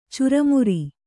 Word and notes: ಚುರುಮರಿ: curumari

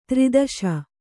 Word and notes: ದುರ್ದೆಶೆ: durdeśe

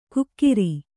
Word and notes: ಕುಕ್ಕಿರಿ: kukkiri

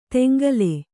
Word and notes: ತೆಂಗಲೆ: teŋgale

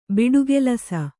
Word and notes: ಬಿಟ್ಟಿಗೆಲಸ: biṭṭigelas

